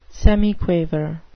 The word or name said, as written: semiquaver